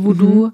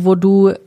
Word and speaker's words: Vodou: Also V-O-O-D-O-O. – Genau, das ist das popkulturelle Voodoo. Mhm. Und das haitianische Voodoo ist V-O-D-O-U. – Ah. – Das wird auch, glaube ich, eher Voodoo dann ausgesprochen oder Voodoo, also mit einer anderen Betonung.